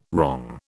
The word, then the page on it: wrong: British English